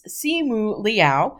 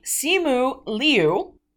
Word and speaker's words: Simu Liu: And he basically gave out a pronunciation guide for his name, which is Simu Liao. Simu Liu.